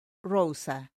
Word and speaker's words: Roza: Claro, soy arroba Margaret Rosa, M-A-R-G-U-E-R-I-T-E. E, y mi apellido es Rosa, R-O-Z-A.